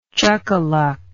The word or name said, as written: chuckaluck